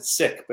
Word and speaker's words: Sikh: And so she's had a lot of churches invite her to come speak, even though she's a Sikh. Or they pronounce it sick, but people get confused.